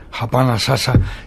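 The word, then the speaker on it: hapa na sasa: que se llama Japanasasa. ¿Se dice así? – Así es, Japanasasa, que quiere decir aquí y ahora en lengua Kiswahili, que es un idioma vehicular del África Oriental.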